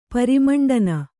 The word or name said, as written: ಪರಿಮಂಡನ